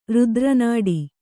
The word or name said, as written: ರಾತ್ರಿನಾಡಿ